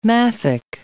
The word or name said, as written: maffick